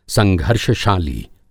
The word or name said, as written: संघर्षशाली